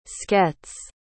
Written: sketes

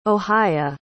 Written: ohia

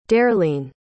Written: darelene

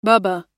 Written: bubba

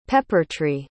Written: peppertree

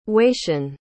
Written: wacian

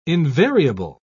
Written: invariable

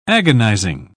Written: agonizing

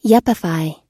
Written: yuppify

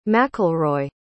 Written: mcelroy

